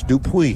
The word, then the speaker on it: Dupuy: In case you're wondering, Alex Dupuy is spelled D as in dog, D-U-P as in pine, U-Y. It's Dupuy.